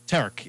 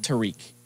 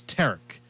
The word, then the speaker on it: Tarek: Tarek. – It's not like Tariq. – No, it's Tarek. But just like Derek, but with a T.